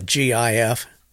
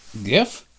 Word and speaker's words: GIF: I always say gif, like gift, but that's not right. It's got a gif. – It's pronounced like the peanut butter.